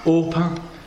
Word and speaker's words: Orpah: So at this point, Naomi has met her daughters-in-law, Ruth and Orpah. Orpah. Yeah, that's a hard one to... Oprah is actually called after Orpah. It's just that they read the name wrong. It's hard to read, isn't it? Oprah, yeah. So Oprah is actually Orpah, just with the words muddled up.